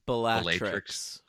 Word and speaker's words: Bellatrix: Bellatrix. – Oh, okay. – Yeah. – I tend to draw out the long A.